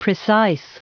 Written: precise

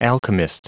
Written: alchemist

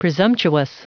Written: presumptuous

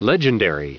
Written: legendary

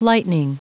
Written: lightning

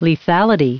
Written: lethality

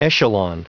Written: echelon

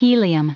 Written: helium

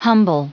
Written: humble

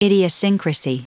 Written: idiosyncrasy

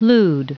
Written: lewd

